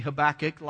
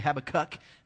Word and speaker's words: Habakkuk: The correct way would be Habakkuk. A lot of people say Habakkuk.